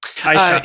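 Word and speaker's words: Ayesha: Aisha.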